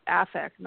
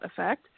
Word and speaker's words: affect: The noun form of with an A is pronounced differently. It's pronounced affect, not effect.